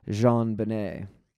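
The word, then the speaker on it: JonBenét: And some other interesting facts is that JonBenet was named after her father, John Bennett Ramsey, and her name is pronounced in a French style, JonBenet.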